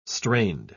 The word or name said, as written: strained